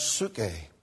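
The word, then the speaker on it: psuche (ψυχή): Now, the Greek word for soul is pronounced psuche.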